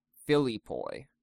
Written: Philippoi

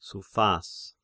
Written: Suphas

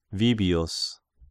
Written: Vibius

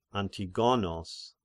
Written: Antigonos

